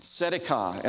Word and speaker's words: tzedakah: Now, I want us to know how to say this word. The TS is tzedakah.